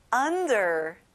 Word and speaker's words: under: 아래에 under, 친구들 on이 아니라 아 소리 내는 거예요.